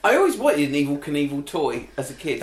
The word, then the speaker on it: Knievel: Like Robbie Knievel just sat there and went, Awful Knorfel, Knievel, rhymes with evil, I'll just change the spelling of evil, and that's how he came up with Evel Knievel.